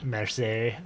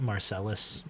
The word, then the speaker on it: Marseille: Marseille. – Very bad. – Marseille. My history teacher always said, don't pronounce that Marcellus, Marcelli. – Marcellus? – Because you'll sound like a loser.